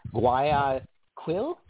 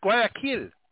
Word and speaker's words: Guayaquil: Guayaquil? Ecuador? – I think it's Kiel. I think it's Guayaquil. – Guayaquil. Guayaquil. – I think the Q is like a K sound, I think, if I remember.